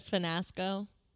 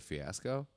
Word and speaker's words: fiasco: Hannah Ann said finasco. Did you catch that? – Instead of fiasco? – Yeah. She said finasco with an N.